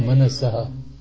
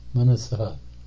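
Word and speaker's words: Manasaha (मनसः): There is Visarga, no? Manasaha. Manasaha.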